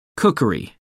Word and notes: cookery: American English

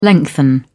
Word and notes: lengthen: British English

